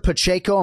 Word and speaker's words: Pacheco: Larissa Pacheco, am I pronouncing that correctly? – Yes, you got it.